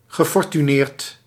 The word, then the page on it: riche: France; [ʁiʃ]